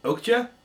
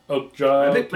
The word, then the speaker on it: Okja: He did a couple of really excellent films, most recently Okage. I think that's how... – Okja? – Okja.